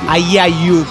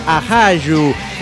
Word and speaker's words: ajayu: Usted se confundió con la palabra alerra, la desintegrada de Desaguadero, ya que no es ayayu, como dijo usted, sino que es ajayu. Y a lo mejor lo estoy pronunciando mal. Y aquí es A-J-A-Y-U.